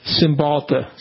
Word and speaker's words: Cymbalta: I noticed that the Cymbalta, is that how you pronounce it? – Cymbalta.